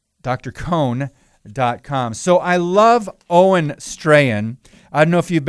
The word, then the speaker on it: Strachan: Owen Strahan, his last name is spelled S-T-R-A-C-H-A-N, but I believe it's pronounced Strahan. And I have a quote from him,